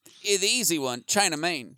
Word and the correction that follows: China Mang: It's actually pronounced China Mang.